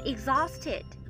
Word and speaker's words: exhausted: 当中的 h 是不发音的，exhausted 是比 tired 更为强烈的那种疲惫，表示精疲力尽的意思。 I'm tired 和 I'm exhausted 都是表达我好累哦的意思，在国外是非常常见的说法哦。